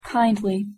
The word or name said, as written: kindly